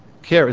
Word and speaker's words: chara: In the Greek, the word most often translated joy is chara, spelled C-H-A-R-A. One of my daughters just picked her head up. Chara is pronounced chara.